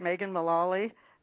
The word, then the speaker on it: Megan Mullally: Am I pronouncing that right, Megan Mullally? – Yes.